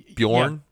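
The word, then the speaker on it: Björn: Bjorn. Yeah, Bjorn. Soft J. J. All right. Hors d'oeuvres. Bjorn. No soft J. – H-O-R-S space.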